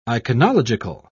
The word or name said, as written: iconological